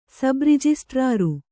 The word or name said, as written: ಸಬ್‍ರಿಜಿಸ್ಟ್ರಾರು